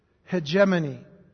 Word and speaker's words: hegemony: The word is hegemony. H-E-G-E-M-O-N-Y.